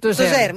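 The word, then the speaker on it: Tozeur: Destacaries alguna cosa de Tozur, no sé si pronuncio bé. – Tozer.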